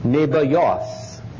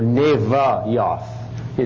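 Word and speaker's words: Nebaioth: The firstborn of Ishmael was Nebayoth, or Nevayoth. It is sometimes spelled with a V instead of a B. Victor versus boy. And you think, well, yeah, B, V, V, but what am I saying, V or B?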